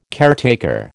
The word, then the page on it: caretaker: American English